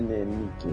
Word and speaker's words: Nike: É, a pronúncia é Nike.